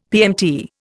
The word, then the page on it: PMT: Indian